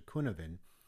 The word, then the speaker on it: Cynefin: That's the Cynevin framework that spells C-Y-N-E-F-I-N, but you pronounce it as a Cynevin. It's a Welsh word that signifies the multiple factors in our environment and our experiences that influences us in ways we can never understand.